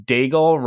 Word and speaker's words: Daigle: My co-host this week is Cliff Daigle, rhymes with bagel, a.k.a.